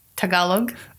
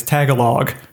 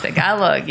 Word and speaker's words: Tagalog: Tagalog, yes. I think, yeah, a lot of Americans pronounce it that way, but it's Tagalog.